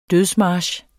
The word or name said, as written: dødsmarch